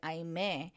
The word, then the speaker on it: Aime: I'm like, my name's Aime. My name's Aime, and my family pronounces it Aime.